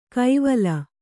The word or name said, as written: ಕೈವಲ